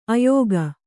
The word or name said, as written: ಆಯುಗ